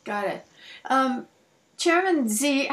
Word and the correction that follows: Xi: how do you pronounce his name? X-I. – X-I? It's Chairman Xi, yeah.